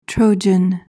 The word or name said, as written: trojan